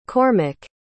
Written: Cormic

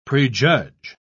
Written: prejudge

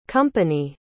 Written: compagnie

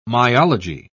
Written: myology

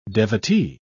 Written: devotee

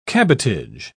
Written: cabotage

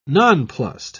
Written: nonplussed

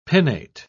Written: pinnate

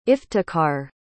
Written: Iftikhar

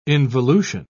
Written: involution

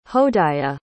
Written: hodaya

